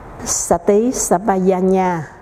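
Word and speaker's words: Sati Sampajañña: Chữ N có dấu ngã, mình đọc là chữ nhờ, còn chữ J đọc chữ giờ, thành ra thầy đọc là Sati Sambayanya. Còn chữ xà tí đó, cái chữ đằng sau không có dấu quyền, thành thử phải đọc nó lên cao hơn chút, giống như có dấu sắc vậy.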